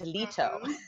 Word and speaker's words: Leto: Like some people call me Trish Leto and I'm like, no, it's Leto.